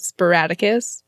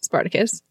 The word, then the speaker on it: Spartacus: So they watch Some Like It Hot and what Cher pronounces as Sporadicus, but it's Spartacus.